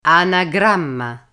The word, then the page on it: anagramma: Italian